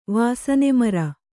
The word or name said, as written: ವಾಸನೆಮರ